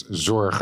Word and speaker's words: Zorge: Yeah, and whilst we're playing the game, it's Zorge. Yeah. Typical Dutch.